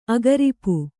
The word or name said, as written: ಅಗರಿಪು